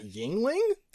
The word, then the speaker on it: Yuengling: You pronounce that Yingling? – Yeah.